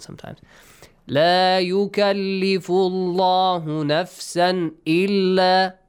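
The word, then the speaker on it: La yukallifullahu (لا يكلف الله): The word Allah, the lam in the word Allah, is pronounced heavily because there is a dhamma sound before. La yukallifullahu. Yukallifullahu, right? – Yes. – The rule says if you have a dhamma or a fatha sound before, pronounce the lam heavily.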